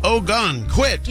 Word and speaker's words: Ogunquit: It's like Ogunquit, Maine. – Ogunquit. – Yeah, exactly. That's how I kept saying how to spell it.